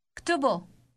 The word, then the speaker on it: اكتبه: اكتبه.